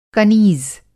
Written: कनीज़

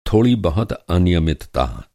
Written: अनियमितता

